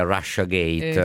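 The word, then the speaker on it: Russiagate: Comunque effettivamente credo che la pronuncia più usata sia Russia Gate. – Infatti all'inglese.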